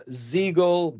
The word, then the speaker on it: Ziegel: Also, if you're in Portland, Maine, me and Jonathan Ziegel, rhymes with Eagle, we're going to be at the Hill Arts Center, March 29th and March 30th.